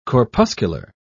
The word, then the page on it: corpuscular: British English